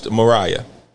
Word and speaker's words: Murraya: Oh, but not what you think. It's pronounced Murraya.